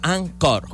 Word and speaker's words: Anchor: Se escribe Anchor ¿ok? Se pronuncia Anchor.